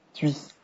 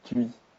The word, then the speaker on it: Tui: Tui. – Tiens, en fait, le ou, il se prononce u plus que ou, en fait. – Tui.